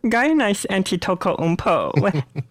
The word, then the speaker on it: Antetokounmpo: Got a nice Antetokounmpo.